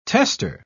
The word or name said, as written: tester